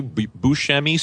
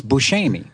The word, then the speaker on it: Buscemi: In Sicily, it's Buscemi. – Buscemi. – Yeah, Buscemi. Okay. – It's a Buscemi. – You are. – So okay. I did say it right. – I always said. – What's up? – So I did say it right at the beginning. Buscemi. Buscemi. – It's a Buscemi. – It's a Steve Buscemi. – Apparently, we've been saying Buscemi, but it's Buscemi.